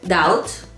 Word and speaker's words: doubt: Doubt. – Doubt. – Claro, pero ¿cómo se escribe? – Se escribe así, así. D-O-U-B-T. – Pero. La B no se pronuncia. – Y la O se pronuncia A.